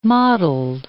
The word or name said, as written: mottled